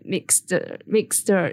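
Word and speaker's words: Maegzter: So today we have Megan, who you might know as at Mixter. Is that how you pronounce it? – Yeah, Mixter's fine. – Mixter. So it's M-A-E-G-Z-T-E-R on TikTok.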